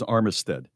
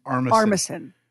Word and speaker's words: Armisen: Did I mispronounce his name? I thought it was Armistead. – It's Armisen.